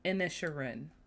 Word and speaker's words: Inisherin: The Banshees of Inisherin.